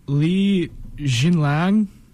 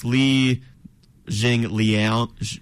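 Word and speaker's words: Li Jingliang: Li Jinlang Gonna give you an X on that one. – You're really close. It's Li Jing Liang.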